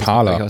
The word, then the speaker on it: Parler: Also das ähm, Amazon, äh, pa wie, wie, spricht man das aus? Parler. Aus es ist Französisch, oder?